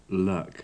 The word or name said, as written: luck